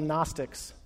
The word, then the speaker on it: Gnostics: There was a group, a sect in Jesus, well, in the early church's day called the Gnostics. That is a silent G, which I think is fascinating at the beginning of the word Gnostic, okay?